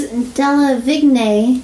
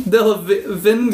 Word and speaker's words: Delevingne: You left down the letter. It's Delevingne. – Delevingne. – Yes. – Delevingne.